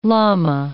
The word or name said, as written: llama